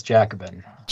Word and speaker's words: Jacobin: As you were published in this magazine, how do you pronounce it? Is it Jacobin? – That's the one. It's Jacobin.